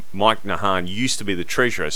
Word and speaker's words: Nahan: Is that how you pronounce it? – Yep. – N-A-H-A-N? – That's correct, yeah.